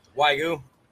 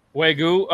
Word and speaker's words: Wagyu: Wagyu. – Wagyu.